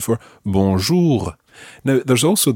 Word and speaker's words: bonjour: For English native speakers, the R at the end of bonjour is quite tricky to pronounce, and we'll be spending more time on that in a later lesson. Try to get something similar to it for the time being.